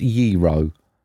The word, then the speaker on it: gyros: Do you know it's pronounced gyro, right? It's spelled G-Y-R-O-S. – Right. – And he wants me to pronounce it gyro.